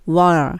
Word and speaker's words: What: ここの時に What are ではなくて、この What の t もどちらかというとブロークになって L に近い発音になって What are, what are もう t は言わないですね。